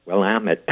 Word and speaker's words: Willamette: And so it would be pronounced how? – Willamette.